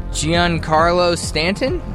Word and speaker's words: Giancarlo Stanton: Baseball standout, Giancarlo Stanton? – Nice.